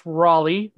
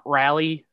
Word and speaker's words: Raleigh: It's pronounced Raleigh, not Rally or anything like that.